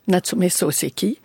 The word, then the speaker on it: Natsume Soseki: Et on passe au début du XXe siècle avec Natsumi Soseki, si je prononce bien. – Natsume Soseki.